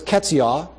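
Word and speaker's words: Keziah: Verse 14, and he called the name of the first, he had daughters here, Yemiah, and the name of the second was Ketziah. That's how it's pronounced there. Looks different than it's pronounced.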